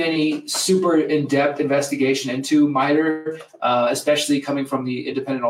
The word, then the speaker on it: MITRE: So I've mentioned a couple of times, I was saying Meter. I got a comment here saying that it's the MITRE Corporation.